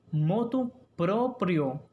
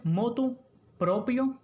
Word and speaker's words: motu proprio: Y escuche bien, aunque le parezca rara, aunque nos cueste pronunciarla, lo adecuado es motu proprio, ¿Escucharon bien? Motu proprio. No es motu propio, ni de motu propio, ni por motu propio.